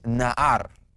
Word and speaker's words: na'ar: When you look at the word little children, the word for children there is na'ar, na'ar.